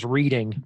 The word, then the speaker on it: Reading: And the next match is Reading. Is that correct? – I think it's pronounced Reading, but yeah. – Reading? Is it Reading? – No, it's Reading.